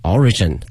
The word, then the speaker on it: origin: 那么这个词啊，表示一个起源，一个源头，难度呢不是很难，但是呢我们很多同学会把它这个发音念错了，会把这个重音放到第二个这个 R 上啊，所以说一定要注意了，它的这个发音的重音在 O 上， origin can be 追溯啊，